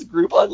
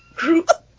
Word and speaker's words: Groupon: She puts the accent on the P. – Groupon. – I've tried to explain it so many times that it rhymes with coupon.